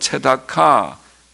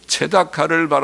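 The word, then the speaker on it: 체다카 (צְדָקָה): 원문에는 공평과 포악, 공평이 미슈파트, 히브리 말은 미슈파트, 포악이라는 말은 미스파크, 발음이 비슷하죠. 미슈파트, 미스파크, 또 의로움이란 말은 체다카, 그리고 부르짖음은 체아카. 하나님께서는 비슷한 발음의 두 단어를 사용하시면서 대조하십니다.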